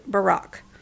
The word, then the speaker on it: Barak: And while she was sitting under her palm tree, she called a man named Barak. I think it's pronounced the same as the name of President Barack Obama, but it's a different spelling and obviously not the same guy because, you know, this is an ancient story.